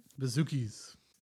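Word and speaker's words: bouzoukis: Like, she plays harp, then there's a hurdy-gurdy, then there's, like, these, you know, bazookas. How do you say it? – Bazookies. – Bazookies, not bazookas.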